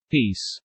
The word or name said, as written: peace